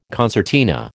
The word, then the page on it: concertina: American English